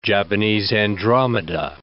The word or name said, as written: Japanese andromeda